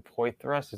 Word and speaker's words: Poythress: I didn't notice that you have Tripper Longman, Pete Enns, Vern Poythress.